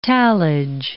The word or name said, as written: tallage